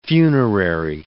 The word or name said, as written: funerary